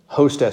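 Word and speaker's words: Hosta (ὥστε): Hosta. – Hosta. – So it's a rough breather. Omega, ho. And then sigma, tau, epsilon. – Okay. – Hosta. Yeah. Hosta.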